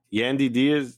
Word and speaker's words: Yandy Diaz: I believe this is Yandy, but I don't want to butcher his first name here. Yandy Diaz, exactly.